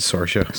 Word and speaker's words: Saoirse: Who's Saoirse Ronan? – She pronounces it Saoirse.